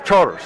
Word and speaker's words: Chartres: Yes, Charters. We got to help with the southern pronunciation. – It's murder. – It's spelled like it's Chartres, but it's called Charter Street.